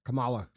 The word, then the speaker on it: Kamala: where he's reading off a teleprompter and him and Camilla are talking back and forth. Or what's her name? – Kamala. – Kamala. I know everybody gets in trouble for pronouncing it wrong, so I want to make sure I pronounce it correctly.